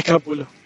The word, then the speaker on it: Capullo: Debería haber pronunciado italiano y no en español. – Capullo. – Sí. – Sí, es Capullo.